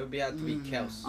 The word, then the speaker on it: Kelce: Did you see how everyone's been saying Travis Kelce's name wrong? It's Kels.